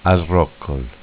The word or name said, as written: Al Roccol